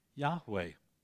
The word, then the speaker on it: Yahweh: It was Y-H-W-H in the consonantal language they had. But when vowel pointing became a reality, done, by the way, by Jewish scholars that knew exactly how to pronounce the name, they vowel pointed it in such a way it sounded like Yahweh.